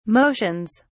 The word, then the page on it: motions: British English